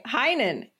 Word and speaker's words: Heinen: No, I'm saying it wrong. – It's all right. Heinen. You're close.